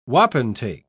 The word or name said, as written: wapentake